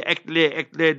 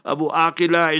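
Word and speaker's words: Abu Akleh: They say Akleh, that's their own style. Abu Akila is the proper pronunciation.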